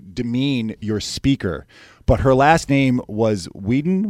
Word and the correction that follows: Weider: was it? – Weider. – Weider, okay, but can pronounce Weider, okay?